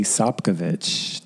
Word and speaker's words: Sopkovich: Mine is T Sopkovich, T S O P K O V I C H.